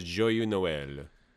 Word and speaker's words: Joyeux Noël: Joyeux Noël. – Joyeux Noël. – Is that how you say it? – Joyeux. – I think yeah, that is correct. – So, yeah, the next one is Joyeux Noël.